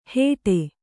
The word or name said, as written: ಹೇಟೆ